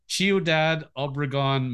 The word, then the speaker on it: Ciudad Obregón: So, born in, this is how I pronounce it as me, Ciudad Obregón, Mexico.